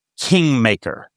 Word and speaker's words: KNGMKR: And then eventually formed a studio that I think is pronounced Kingmaker. Okay. Because the way that it's actually listed, filed, is K-N-G-M-K-R.